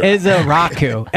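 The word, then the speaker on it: Ezeiruaku: Ezaraku. Ezaraku. – Ezaraku. – That's how you pronounce it.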